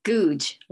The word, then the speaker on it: Googe: Googe, like screw.